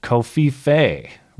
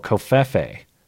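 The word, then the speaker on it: Covfefe: Boom Baby, it is pronounced Kofife, rhymes with Pepe. Oh, Kofefe.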